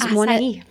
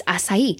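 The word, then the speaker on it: açaí: It is acai. It's acai. – Acai. – Because it is definitely a weird word. Mm-hmm. But it is from South America, and that's how they pronounce it.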